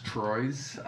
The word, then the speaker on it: Troyes: So I think he could go straight into, like, a Trois and... Be a left back, be their left back. No disrespect to whoever they have at left back at the moment, but I believe it's pronounced Troyes.